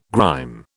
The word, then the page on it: grime: British English